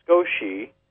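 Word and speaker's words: sukoshi: That the word skosh comes from the Japanese word sakoshi, which is pronounced skoshi and means a tiny bit or a small amount.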